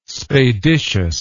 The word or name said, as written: spadiceous